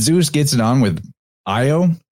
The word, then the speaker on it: Io: Io would be pronounced like yo, and the A and the ah sound are easily interchanged between languages.